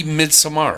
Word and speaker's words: Midsommar: No, it's phonetically Midsommar. – Oh my God. – It's phonetic.